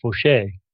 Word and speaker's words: Faucher: Yeah, I didn't spell his name like it, you know, Mike's name is F-A-U-C-H-E-R. And unless you know the French pronunciation, which is Fauché. You wouldn't you wouldn't get it. So I spelled it phonetically, F-O-S-H-A-Y, so you couldn't possibly not say it right. But, or you could have used F-E-U-X, right, for faux.